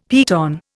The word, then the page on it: piton: Indian